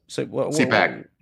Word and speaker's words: CPAC: So, first of all, is it CPAC or CPAC? – CPAC. – CPAC, yeah, I don't know if I got Tupac on the brain, you know what I mean? I just want to pronounce it that way. C-pocalypse now, we had talked about, but I guess we'll go with the short A, if that's correct.